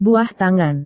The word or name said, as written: buah tangan